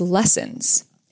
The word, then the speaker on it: Lessans: And I will be taking my partner Scott's last name, and his last name is Lessons, L-E-S-S-A-N-S, but it's pronounced like the word lessons, like L-E-S-S-O-N-S.